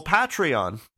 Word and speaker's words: Patreon: Well, Patreon, that's how it's pronounced.